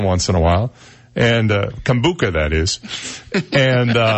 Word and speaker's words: kombucha: I always pronounce K-O-M-B-U-C-H-A. You know what that is? – Kombucha.